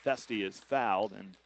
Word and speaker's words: Foeste: Jack Festy, spelled F-O-E-S-T-E. Pronounced Festy.